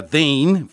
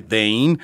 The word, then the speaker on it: Daine: Llega el turno de mm, eh, esta artista joven, eh, muy talentosa, Dane, firma esta eh, chica, D-A-I-N-E, como se escribe su nombre, Dane.